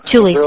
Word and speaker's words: Thule: Now, you know that Hitler may have been mentored by personalities deep in the occult, such as members of the, am I pronouncing it correctly, the Thule Vril Societies. – Thule. – And the Thule Society, yeah. – Thule, thank you.